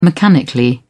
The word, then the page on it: mechanically: British English